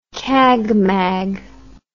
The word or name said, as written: cagmag